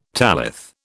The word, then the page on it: tallith: British English